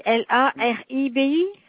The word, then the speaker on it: Laribi: Le nom, ça s'écrit LARIBI? – C'est ça, monsieur Laribi.